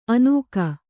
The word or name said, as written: ಅನುಗ